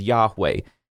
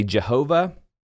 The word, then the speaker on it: Yahweh: But when you see "Lord," it's probably small caps, and the English letter equivalent would be YHWH, and the best pronunciation we can get in English is Yahweh. Now, there are some interesting things going on there with the vowels, which we won't get into in this podcast, but this name was written so as to make it actually hard to pronounce. Your King James Bible, if you have that, will sometimes say Jehovah, which is kind of close, not quite there.